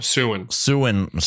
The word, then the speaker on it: Siouan: So it is in the North American Great Plains, historically inhabited by speakers of the Siouan languages. – Siouan. Oh, Siouan. – Sorry. My apologies on the mispronunciation there. Siouan languages.